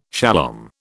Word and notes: shalom: British English

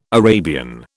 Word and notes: Arabian: British English

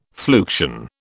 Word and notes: fluxion: British English

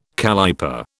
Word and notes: calliper: British English

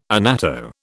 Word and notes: annatto: British English

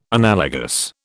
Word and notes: analogous: British English

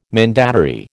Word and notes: mandatary: American English

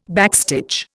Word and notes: backstitch: Indian

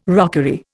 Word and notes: rockery: Indian

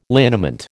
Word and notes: liniment: American English

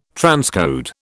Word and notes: transcode: British English

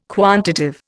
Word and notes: quantitive: Indian

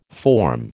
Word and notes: form: American English

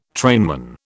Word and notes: trainman: British English

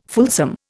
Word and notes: fulsome: Indian

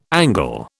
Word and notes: Angle: British English

